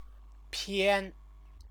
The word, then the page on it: pian: Chinese